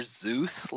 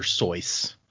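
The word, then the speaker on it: Seuss: It sounded like you said Dr. Zeus, like the god of thunder and lightning. – Oh. – So somebody then I just told me, like, yesterday that his name was originally supposed to be pronounced Dr. Sois.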